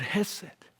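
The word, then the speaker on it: chesed: I know you see a C there, but the C is silent. And there's really a Hebrew growl beneath that word hesed. I can't reproduce it, so everybody just say hesed.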